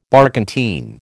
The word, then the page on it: barkentine: American English